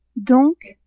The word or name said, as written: donc